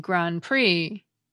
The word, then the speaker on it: Grand Prix: The same goes for the silent P and silent T in coup d'etat, and the silent D and silent X in Grand Prix.